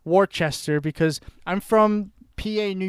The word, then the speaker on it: Worcester: I remember when you came out and visited and you looked at that and you're like, Worcester? I'm like, no, it's pronounced Worcester.